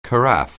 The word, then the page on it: carafe: anglais